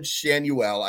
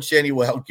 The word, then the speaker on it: Schanuel: Uh, I just uh, Nolan Schanuel. I just wanted to make sure I pronounced it right because there's lots of ways you could go with that name, but I did just look it up.